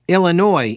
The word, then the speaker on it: Illinois: Dan says, as a native, I can confirm it is pronounced Illinois,